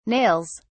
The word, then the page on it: nails: British English